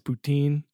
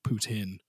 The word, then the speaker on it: poutine: If you get the Francophone pronunciation, it's poutine. And if it's, you know, your Anglophone kind of way, it's like poutine, but, okay.